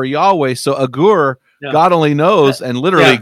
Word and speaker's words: Agur: But you're right, chapter 30 is written by this guy, A-G-U-R, and it's so interesting that no one knows even how to, he's so obscure, Agur, A-G-U-R, is so obscure, no one knows how to pronounce it. So I rhyme it with Agur, the prayer of Agur, but I think I've heard it the way you pronounce it there as well.